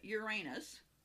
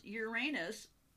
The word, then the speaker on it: Uranus: Can you pronounce Uranus without snickering? Most people are taught that the name of the tilted planet sounds like your anus, a pronunciation sure to elicit snickers.